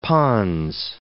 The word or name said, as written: pons